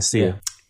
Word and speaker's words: Sia: With Vic Mensa and Sia. Am I pronouncing that right? – I think it's Sia, yeah, Sia. Yeah.